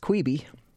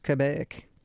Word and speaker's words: Quebe: All right, next up, recommended by the fabulous Jack Rummel, a CD from these sisters whose last name is Q-U-E-B-E. So if you were raised up in Texas like I was, that's probably pronounced Queeby. And if you're from Quebec, it's probably pronounced Quebec. Quebec sisters, somewhere in there, the Quebe sisters.